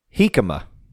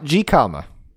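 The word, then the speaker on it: jícama: And don't forget, it's pronounced Hikama, not Gikama.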